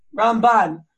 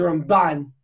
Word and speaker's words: Ramban: So the way you say it is, you have the Rambam, you put the accent on the first syllable, then you have the Ramban. You put the accent on the second syllable.